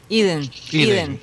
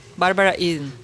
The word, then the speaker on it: Eden: Pero hoy, está cumpliendo años eh, mi bella genio, Bárbara Eden, o Eden, ¿cómo se pronunciaría? – ¿Cómo se escribe? Eden. Eden. Iden, Iden, Bárbara Iden.